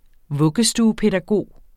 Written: vuggestuepædagog

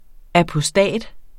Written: apostat

